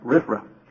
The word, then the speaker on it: RFRA: The act is abbreviated RFRA, and then you supply a vowel to pronounce it RFRA.